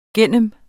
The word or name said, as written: gennem